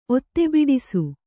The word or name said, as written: ಒತ್ತೆಬಿಡಿಸು